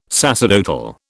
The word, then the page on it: sacerdotal: British English